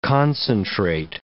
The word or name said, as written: concentrate